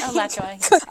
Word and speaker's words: Alachua: Our next guest is from the Alachua. Did I pronounce that correctly? – Alachua.